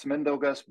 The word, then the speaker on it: Mindaugas: Yeah, you almost got that right. It's Mindaugas, but no offense taken.